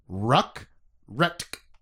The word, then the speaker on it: Ruk-Retk: Well, his name is Colonel Sorry, I didn't even say that right. I got the T and K in the wrong order. Colonel Ruk-Retk.